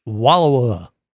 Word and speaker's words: Wallowa: Wallowa.